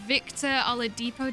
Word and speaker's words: Oladipo: Victor Oladipo, did I say his name? – Yes.